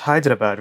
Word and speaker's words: Hyderabad: Well, first of all, can I say you pronounced Hyderabad really well? – Thank you.